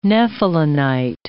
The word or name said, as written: nephelinite